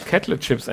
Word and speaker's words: Kettle Chips: Wir haben uns heute für die Kettle Chips, oder wie spricht man das aus? Ja, richtig. Kettle Chips entschieden. – Kettle, ja, ist richtig.